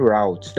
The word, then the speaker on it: route: Your is it route that they used to pronounce it as? – Yes.